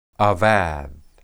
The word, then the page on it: abad: hebrew